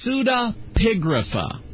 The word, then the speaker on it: pseudepigrapha: And the Book of Enoch belongs to what's called pseudepigrapha. Accent on the pig part.